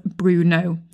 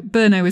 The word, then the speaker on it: Brno: Anyway, my point being, I've been mispronouncing Brno. That's the correct pronunciation. In this episode, I keep referring to it as Bruno. So Brno is the correct way to say this city name. B-R-N-O.